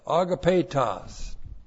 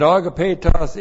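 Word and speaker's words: agapetos (ἀγαπητός): And then we have a verbal adjective, agapetos. A-G-A-P, long E-T-O-S, agapetos. And agapetos is a verbal adjective, and it's in the vocative case.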